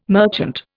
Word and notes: merchant: Indian

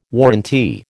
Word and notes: warrantee: American English

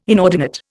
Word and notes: inordinate: Indian

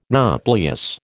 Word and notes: nauplius: American English

